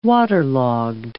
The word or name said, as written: waterlogged